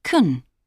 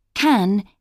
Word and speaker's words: can: In positive sentences and questions, can is pronounced kun. For example, can you use euros in Norway and Sweden? – I think you can use euros in Sweden, but not in Norway. In short answers, can is pronounced can.